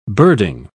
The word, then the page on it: birding: British English